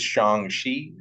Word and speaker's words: Shang-Chi: I used to just say Shang-Chi, but I don't think that's right. Is Shang-Chi, is that correct? – I think that's right.